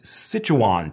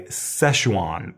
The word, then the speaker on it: Sichuan: This is Sichuan peppercorn. I guess Americans say Szechuan peppercorn.